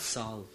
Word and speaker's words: salve: Is it salve? – Salve. – Salve. The L is silent? – Yeah.